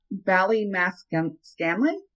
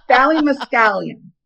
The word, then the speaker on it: Ballymascanlon: Bally Mescalion. It's spelled B A L L Y M A Scanlon. – Ballymascan Scanlon then. Yeah, Ballymascanlon. Scanlon. So fun.